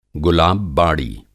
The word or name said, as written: गुलाब-बाड़ी